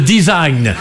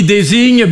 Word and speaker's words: design: Alors je sais qu'il vaut mieux prononcer à la British, hein, le design. Parce que si on dit désigne, bah, on passe pour un plouc.